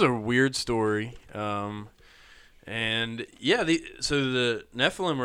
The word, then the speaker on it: Nephilim: No, it's actually Nephilim.